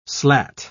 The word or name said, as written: slat